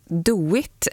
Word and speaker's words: DOIT: I valet så var ett av partierna som gick framåt, det lokala partiet, de oberoende i Torsby, förkortat DOIT. Är det så man uttalar förkortningen, Aron? – Ja, men så är det.